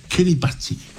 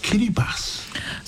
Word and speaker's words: Kiribati: Now, for many years, I kept saying Kiribati, not realizing that it's actually pronounced Kiribati. – So the T-I is a S.